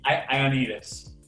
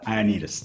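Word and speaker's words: Ioannides: I felt that Ali Oneida, I'm going to say her name wrong. – I'm just going to say Ali. Ioannidis. Yeah. – Ioannidis, thank you.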